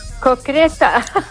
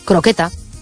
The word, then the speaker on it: croqueta: Se acepta concreta porque como hay gente que lo pronuncia muy mal, pues entonces han decidido que vale igual. Pero bueno, la palabra correcta es croqueta.